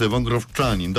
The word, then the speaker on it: Wągrowczanin: miejscu MKS Nielba Wągrowiec, co pewnie interesuje kapitana Grunwaldu Poznań Piotra Nowickiego, bo to jest Wągrowczanin, dobrze wymawiam, prawda? – Dokładnie.